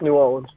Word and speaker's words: New Orleans: We're going to New Orleans. Or did I mispronounce that? – No, you're good. It's New Orleans.